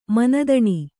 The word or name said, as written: ಮಾನಧನ